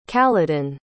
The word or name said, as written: caledon